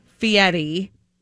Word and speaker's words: Fieri: Fieri.